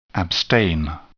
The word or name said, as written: abstain